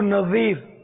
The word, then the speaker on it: نذیر: ابو نذیر زال سے وا سے نہیں زال نذیر ابو نذیر کبھی رمضان شریف میں احتکاب کیا 30 دن کا میں اس پر کریں گے انشاءاللہ پورے ماہ کا.